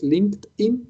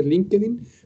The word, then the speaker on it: LinkedIn: como se pronuncia en inglés, LinkedIn, pero nosotros decimos en, chilense LinkedIn, para que más o menos sepan quién soy, ahí está el perfil profesional.